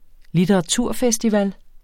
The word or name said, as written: litteraturfestival